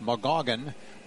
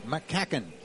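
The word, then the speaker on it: McCaughan: All right, Darren McCacken, spelling it M small c, capital C, A-U-G-H-A-N. It looks like McGoggin, but it's pronounced McCacken.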